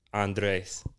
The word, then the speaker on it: Andres: So, I'm Italian and German. Technically, it's like Andres.